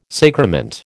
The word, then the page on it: sacrament: American English